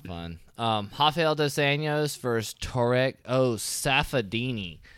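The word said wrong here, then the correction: Saffiedine: That was Tarek Safadine. – There we go, Safadine. Yeah, butchered that one.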